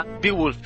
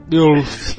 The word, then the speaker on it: Beowulf: Beowulf. – Beowulf.